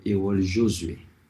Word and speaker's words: Errol Josué: Pero en Haití lo pronunciamos como Errol Josué. Como en francés.